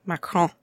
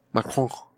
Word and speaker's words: Macron: Macron. – Macron.